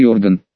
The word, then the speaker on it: орган: Как и не осознавал, что даст мне этот долбанный новый орган. С ударением на «о», само собой.